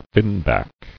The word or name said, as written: Finback